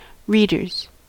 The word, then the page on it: readers: American English